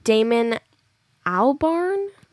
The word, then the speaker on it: Damon Albarn: The lead singer of Gorillaz, mm-hmm, is Damon Albarn. – Okay. – That's how you pronounce it.